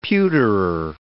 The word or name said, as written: pewterer